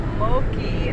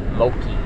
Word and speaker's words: Moqui: Moki, and it was spelled just like that sign. – M-O-Q-U-I. Moki.